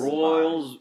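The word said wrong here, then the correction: Rolls-Royce: How do you say it? – Rolls Royce? – Royals, ah, crap, I can't say it. Rolls Royce. Yeah, there we go.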